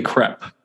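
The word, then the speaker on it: crêpe: You know, From Great to Crepe, it's actually crepe is the proper pronunciation.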